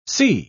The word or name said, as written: sea